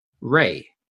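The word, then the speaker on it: Rhea: and pronounces her name Ray. – Ray.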